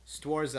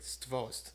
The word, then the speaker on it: Stworz: And so my package finally came in, and it had that album in it, and it also had the new Stwarz album, which is actually pronounced completely wrong. And Stwarz made a post on their Facebook recently about it. Their album, I mean, their band name is actually pronounced more like Stvost. – Huh? – It's like Stvost. So that's how you're supposed to pronounce it.